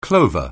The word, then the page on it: clover: anglais